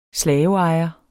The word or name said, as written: slaveejer